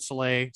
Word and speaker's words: Saleh: Like, here's the, like, I go, as a Lions fan, going into it, I really wanted Robert Saleh.